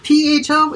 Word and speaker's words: pho: P H O is pronounced pho.